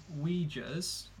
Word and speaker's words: Uyghurs: They've been accused of locking up 1 million Uyghurs, and that is the correct pronunciation because I looked it up.